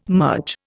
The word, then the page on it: merge: Indian